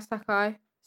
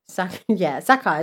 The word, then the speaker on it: Sacai: Sakai is spelled S-A-C-A-I. – Yeah, Sakai. – Sakai. Is that good? – Yeah, I would say Sakai. – That's good pronunciation.